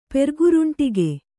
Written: ಪೆರ್ಗುರುಂಟಿಗೆ

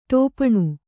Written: ಟಿಪ್ಪಣ

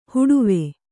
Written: ಹೂಡೇವು

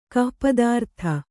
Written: ಗೋಪಿತಾರ್ಥ

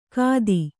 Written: ಖತಿ

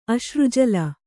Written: ಅಶ್ರುಜಲ

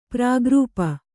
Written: ಪರಾಗ್ರೂಪ

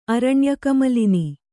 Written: ಅರಣ್ಯಕಮಲಿನಿ

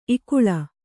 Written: ಇಕ್ಕಳು

